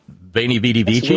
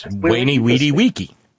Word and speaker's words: veni, vidi, vici: But no, they were speaking, and it was good Latin because they pronounced the letter V as a W, which the Romans did. It's not veni, vidi, vici, really, it's veni, weedy, weeky.